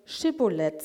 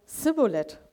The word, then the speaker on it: Schibboleth: Wer nach Gilead einreisen wollte oder dorthin fliehen wollte, musste das Wort Schibboleth sagen. Aber die Ephraimiter konnten deswegen ihrem Dialekt nicht richtig aussprechen. Die haben dann Sibboleth gesagt.